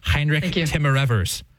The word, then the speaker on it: Heinrich Timmerevers: This is Bishop, and I'll pronounce it, Sarah, so you don't have to, Heinrich Timmerevers.